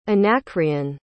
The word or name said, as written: anacreon